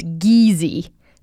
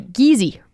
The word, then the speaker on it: Geezy: but it's pronounced Geezy. So, you know, Daniel tries to make little notes, writes it out on how to actually pronounce it. But even easier than that, to help remember off the top of your head, you can say Geezy rhymes with easy. It's easy like Geezy.